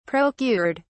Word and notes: procured: British English

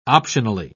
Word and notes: optionally: British English

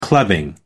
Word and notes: clubbing: American English

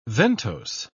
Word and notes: ventose: British English

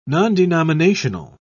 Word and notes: nondenominational: British English

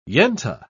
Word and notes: yenta: English; British English

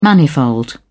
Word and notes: manifold: British English

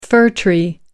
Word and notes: fir tree: British English

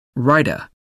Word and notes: raita: American English

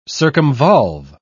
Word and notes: circumvolve: British English

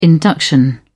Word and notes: induction: British English

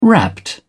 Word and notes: wrapped: British English

